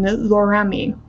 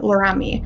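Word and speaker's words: Laramie: Matthew attended high school in Casper, Wyoming, and at the American School in Switzerland before matriarching at the University of Wyoming in Loramie. Excuse me if I mispronounce that. Loramie. I think it's Loramie.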